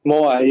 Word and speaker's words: Moai: I mean, the statues are creepy as all get out, but tell us a little bit about the secrets of the Moai. Am I saying that right? – Moai, yes. Yeah, this is my latest investigative series.